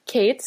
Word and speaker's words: Kate: My Instagram feed is, you know, not Jenna Kutcher, but mine is Kate, so K-A-T-E.